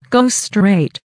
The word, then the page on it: go straight: American English